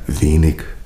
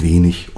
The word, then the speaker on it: wenig: Kleines Beispiel, die berühmte IG-Endung, die ja im süddeutschen Raum gerne hart ausgesprochen wird, also wenig, König, aber nach der Vorgabe, es ist wenig und König.